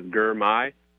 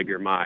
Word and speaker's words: Gurmay: First name is Gurmay. I tell people it's like my guy, Gurmay, if that helps. My guy, Gurmay.